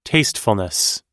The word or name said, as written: tastefulness